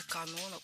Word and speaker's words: Конон: А может и Канона? Конан, наверное, огородник. – Не могу не помочь Маргарите. Конон – это имя, и ударение в этом слове падает на 2-й слог.